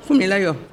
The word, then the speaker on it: Funmilayo: Vous écrivez Une armée de femmes qui se distinguent toujours par leur tenue traditionnelle entoure, comment on prononce Funmilayo Funmilayo.